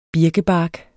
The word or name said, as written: birkebark